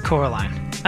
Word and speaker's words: Coraline: Coraline.